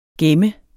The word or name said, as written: gemme